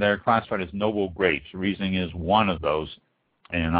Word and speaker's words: Riesling: So we are going to talk about the Riesling grape, spelled R-I-E-S-L-I-N-G, Riesling. I've heard some people pronounce it wrestling, but the Riesling is the pronunciation I have always heard, I've always used, and everyone I've ever talked to in the industry has always used it.